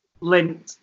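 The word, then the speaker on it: Lindt: these, for the record, how do you pronounce it? Lint? – Lint, yeah.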